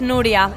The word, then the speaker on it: Nuria: Yes, Nuria.